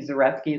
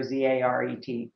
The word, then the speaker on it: Zaretsky: Well, obviously LinkedIn, Janet, J-A-N-E-T, Zaretsky, it's spelled up there, Zaretsky.